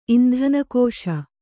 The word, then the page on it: ಇಂಧನಕೋಶ: indhanakōśa